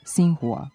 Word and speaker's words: Xinhua: U tom odeljku navedeno je da se X iz kineskog pinjina ispred slogovnog I izgovara kao S. Osim za prezime kineskog predsjednika Sija, to važi, na primjer, i za ime kineske novinske agencije Xinhua.